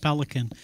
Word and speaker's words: Pelikan: I think it's important, Joe, to come back to a quote by Yaroslav Pelikan. His book, The Riddle of Roman Catholicism, his name is spelled P-E-L-I-K-A-N. Pelikan, in his, I think, very fascinating book, I really like his book, The Riddle of Roman Catholicism.